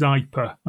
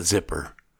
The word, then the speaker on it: Zypper: And the command line access to this is Zyper. That's spelled capital Z-Y-P-P-E-R. – I think Americans might call that zipper.